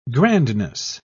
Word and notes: grandness: British English

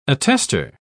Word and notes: attester: British English

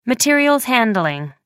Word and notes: materials handling: American English

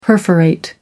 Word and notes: perforate: American English

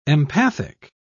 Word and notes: empathic: British English

